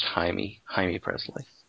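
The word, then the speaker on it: Jaime Pressly: I've seen the Jamie Presley one. The Drew Barrymore one's really good. – Her name is pronounced Jaime Presley.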